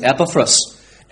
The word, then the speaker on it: Epaphras: I should say from the beginning that the correct pronunciation of this man's name is Epaphras. Epaphras. But I'm from Limavady. So for the duration of this sermon, he's going to be referred to as Epaphras.